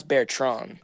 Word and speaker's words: Bertrand: It's pronounced Bertrand. – Oh, that is sick. – In French.